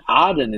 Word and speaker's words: Aden: And Aden, Aden is how it's pronounced in Arabic. We, we called it Aden because we couldn't pronounce ah. I think we British people, as we took Aden, we took it in our colonial way.